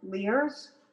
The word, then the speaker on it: Lyre's: So one of the brands is called, I think it's pronounced Lear's, and that's made in Australia. You can tell Jan. – How do you spell it? – L-Y-R-E-S.